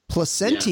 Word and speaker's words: Placentia: Placentia, yeah.